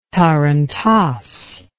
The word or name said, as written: tarantass